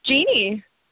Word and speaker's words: Jeanne: Jeannie. – J-E-A-N-N-E. – Yeah. So it's called Jeannie.